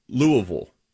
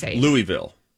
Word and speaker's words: Louisville: Can I just say I appreciate how you said Louisville? – Thank you very much. – You said it all. – I said Louisville. – That's how you say it. – Instead of Louisville. – Right. I grew up close to Louisville, okay. And that's how they say it.